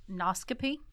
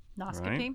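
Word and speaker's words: nauscopy: I think this is pronounced noscopy. Noscopy. – Right. – In 1718, so noscopy is a little known word. It is a science. It's a science. It's N-A-U-S-C-O-P-Y. – Noscopy.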